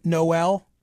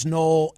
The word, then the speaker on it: Noel: Should I be pronouncing your name Noel? – No, we pronounce it Noel. Although I'm generally, you know, especially around Christmas, especially tolerant of the alternate pronunciation.